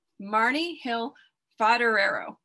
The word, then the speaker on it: Marni Hill Foderaro: Today, my guest is Marni Hill Foderaro.